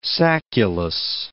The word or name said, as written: sacculus